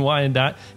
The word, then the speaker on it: Wyandotte: I am here with Ray from Midwest Camera Repair in Wyandotte, Michigan. Is that right, Ray? Did I say that right? – Yep, that's correct. – In Wyandotte.